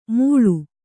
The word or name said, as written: ಮುಳು